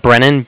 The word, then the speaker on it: Brennan: And it was Brennan, B-R-E-N-N-A-N. – Okay. – With Bank of Cardiff. And that's spelled C-A-R-D-I-F-F.